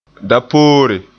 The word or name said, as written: dapoore